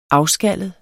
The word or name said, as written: afskallet